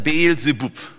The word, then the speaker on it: Baal-Zebub: Also der Name ist eigentlich Baal-Zebub. Ja, oder die Hebräer haben wahrscheinlich Baal-Zebub das ausgesprochen, das B wird dann wie Wuf, aber Baal-Zebub, daher kommt es dann Beel-Zebub.